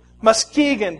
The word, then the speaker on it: Muskegon: Muskegon, that's how you say it.